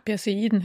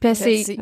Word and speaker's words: Perseiden: Perseiden, hätte ich gesagt. – Perseiden, okay.